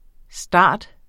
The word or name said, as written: starter